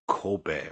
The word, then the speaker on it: Kobe: They pronounce it Kobe, right? Not Kobe.